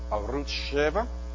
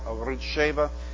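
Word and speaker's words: Arutz Sheva: The one that you want is called Arut Sheva, and I'll spell that for you. A-R-U-T-Z. And then the numeral 7. Or A-R-U-T-Z, and then the numeral 7. Sometimes you'll hear it called Arut Sheva, and that would be A-R-U-T-Z, new word, S-H-E-V-A.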